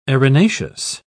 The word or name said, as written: arenaceous